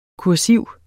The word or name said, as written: kursiv